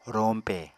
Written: roñpe

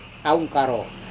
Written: raunkaro

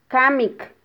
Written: kamik

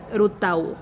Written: rutau

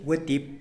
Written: wetip

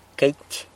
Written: queity

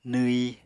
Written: nwyi

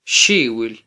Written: xiwil

